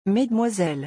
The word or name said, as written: mesdemoiselles